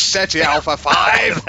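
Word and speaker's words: Ceti Alpha V: How do you say it properly? – This is SETI Alpha 5! – That's right. That's exactly how you say it. And no other way ever.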